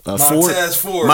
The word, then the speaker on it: Montez Ford: Montez Ford.